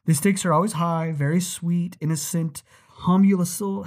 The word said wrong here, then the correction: homunculi: How do you say that word? – Okay. The stakes are always high, from the sweet, innocent homunculi to otherworldly, angelic, ultra-terrestrials.